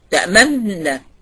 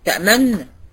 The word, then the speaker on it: تأمننا: تامن بتسكني تامن يعني وتضم، مش عارفه اجيبها ازاي بصراحه على اللاب، انما هي ضم الشفتين بعيد تسكين الحرف، يعني بسكن النون الاولانيه وبعدين بخلي الشفتين في وضع الضمه وبعدين انطق النون التانية، فبتبان كأنها نون واحدة بس. تأمننا تأمننا تأمننا، بتعرفها كده ضم الشفتين بعيد تسكين الحرف.